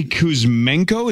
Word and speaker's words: Kuzmenko: Yeah, so Andrei Kuzmenko, is that how you say it, Zachary? – Yes.